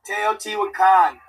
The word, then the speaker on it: Teotihuacan: Teotihuacan.